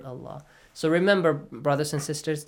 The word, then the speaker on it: Allah: Allah. Yes. Okay. وما الله. Okay. من دون الله فيسبوا. Again, the word Allah is pronounced heavy.